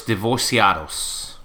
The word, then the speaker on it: divorciados: You said the C was a softer C. The divorciados, maybe? – Oh, huevos divorciados.